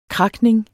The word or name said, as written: cracking